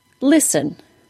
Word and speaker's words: listen: The S-T-E-N pattern, as in the word listen, and the S-T-L-E pattern, as in the word whistle. Both of those patterns are pronounced with no T sound. Let's look at the first word, listen.